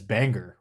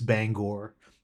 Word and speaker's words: Bangor: I pronounced it as Bangor, which is a lot of people pronounce it that way. And the first thing that they tell you is no, it's Bangor.